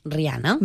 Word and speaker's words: Rihanna: Rihanna.